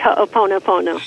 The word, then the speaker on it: Hoʻoponopono: Right, Hoʻoponopono.